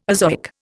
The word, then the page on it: azoic: Indian